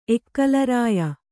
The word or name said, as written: ಎಕ್ಕಲರಾಯ